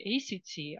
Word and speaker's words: ACT: И если говорить аббревиатуру на английском, то ее произносят как не ACT, а как слово act – это действовать.